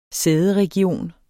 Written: sæderegion

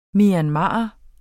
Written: MYANMARER

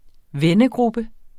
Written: vennegruppe

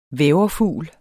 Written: væverfugl

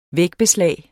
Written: vægbeslag

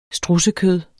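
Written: strudsekød

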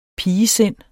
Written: pigesind

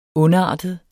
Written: ondartet

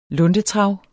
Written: luntetrav

